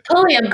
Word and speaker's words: Pulliam: I don't even know if that's how you say her name. But I, was I like, think it's Pulliam. – Pulliam, great, great.